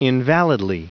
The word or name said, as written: invalidly